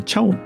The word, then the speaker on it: CHAO: 次にですね、アルファベットで CHAO、これチャオって読むんですね。